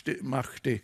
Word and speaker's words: Mahdi: Mahdi stavas det, jag tror det kanske uttalas Mahdi.